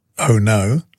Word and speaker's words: ono: When we were chatting about the Hawaiian theme, one resident told me that the Hawaiian word ono, which is pronounced oh no, means enjoyable, pleasing or delicious, which we know our Aloha event certainly will be.